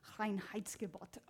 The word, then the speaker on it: Reinheitsgebot: So in Germany, there is this thing called the Reinheitsgebot. That's exactly how you say it,